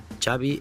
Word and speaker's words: Xavi: Xavi Hernández. – Xavi. – Yeah. – So I heard it. – Xavi like chocolate.